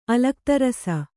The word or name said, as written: ಅಲಕ್ತರಸ